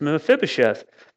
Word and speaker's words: Mephibosheth: It's a very difficult name to pronounce. Okay, but it's Mephibosheth.